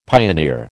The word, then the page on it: pioneer: American English